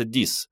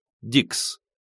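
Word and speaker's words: dix: По-французски «дикс» читается «дис», однако англоговорящие не заморачивались точностью и произносили слово на свой манер – «дикс».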